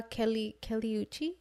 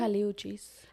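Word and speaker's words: Kali Uchis: I saw Kaliuchi. Is that how you say it? – Kaliuchis.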